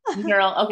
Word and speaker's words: Knerl: Knerl. Okay. I honestly thought so, but I was just like, I don't know, silent K. No, I don't know. – Yep, you're right. – Okay. Miss Lindsey Knerl.